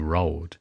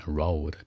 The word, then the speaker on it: road: 有的人说，那两条路可不可以说 two road？你注意还菜的呢是吧？road，road，注意发音，嘴撅起来一点。 road，别肉的肉的啊，那个是道路马路。